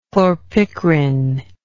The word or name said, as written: chlorpicrin